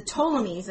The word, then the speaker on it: Ptolemies: The Ptolemies, and that's spelled with a P, were put in place by the land grabber that was Alexander the Great.